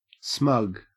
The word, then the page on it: smug: Australian English